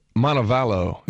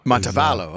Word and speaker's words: Montevallo: Montevallo. – Montevallo, okay.